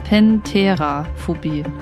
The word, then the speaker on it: Pentheraphobie: Ich glaube, ich habe es falsch ausgesprochen. Pentheraphobie, nicht Theria, sondern Pentheraphobie.